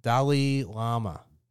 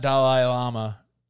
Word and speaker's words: Dalai Lama: Excuse me, where he builds a transformative friendship with the young Dalai Lama. – I knew you were gonna say it like a New Yorker, not Dalai Lama, Dalai Lama.